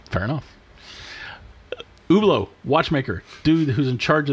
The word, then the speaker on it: Hublot: So the other part of this rumor was the head of Hublot, which is written like Hublot, but it's Hublot.